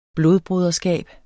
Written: blodbroderskab

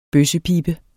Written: bøssepibe

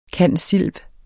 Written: cand.silv.

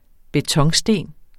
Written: betonsten

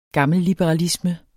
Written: gammelliberalisme

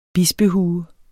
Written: bispehue

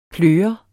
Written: pløret

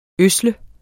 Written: ødsle

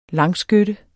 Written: langskytte

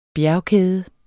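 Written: bjergkæde

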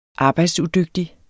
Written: arbejdsudygtig

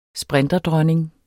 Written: sprinterdronning

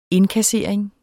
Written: indkassering

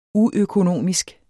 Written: uøkonomisk

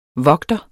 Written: vogter

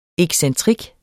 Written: excentrik

